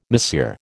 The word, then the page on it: Monsieur: American English